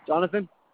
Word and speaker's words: Jonathan: Jonathan. – Okay. Can you spell it for me? – J-O-N-A-T-H-A-N.